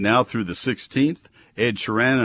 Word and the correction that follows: Sheeran: and all, uh, if I'm pronouncing that correctly, and all Sharon, thank you, Mike. Mike stood all he could. Man, he sounded insistent in my headphones. All right, Ed Sheeran and all the other adult dogs and cats.